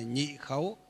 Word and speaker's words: nhị khấu: Chúng ta nhớ nhé, đoạn này lần thứ hai thì đọc là nhị khấu tức là lần thứ hai, lần thứ ba thì đọc là gì, tam khấu tức là lần thứ ba.